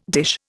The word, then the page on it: dish: Indian